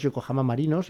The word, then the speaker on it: Yokohama Marinos: Japón y que jugó en los Yokohama Marinos, ya me dirás si lo he pronunciado bien, sobre todo Marinos.